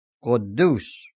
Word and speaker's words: Quddus: The first U without accent mark is pronounced O, as in only, and the second U with the accent mark is pronounced U, as in moon. So it properly is pronounced Quddus.